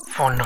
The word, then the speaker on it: Phaune: Phone radio. I mean phone radio. It's spelled P-H-A-U-N-E. But it's pronounced phone.